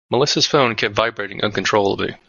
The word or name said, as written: vibrating